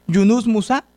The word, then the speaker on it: Yunus Musa: Yunus Musa, ¿lo pronuncié sí, Sí.